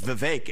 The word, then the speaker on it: Vivek: I say, my name's Vivek. It rhymes with cake.